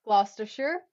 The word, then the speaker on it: Gloucestershire: Gloucestershire, Hampshire, Herefordshire, Hertfordshire, Herefordshire, Hertfordshire, Huntingdonshire, Lancashire, Lincolnshire, Leicestershire.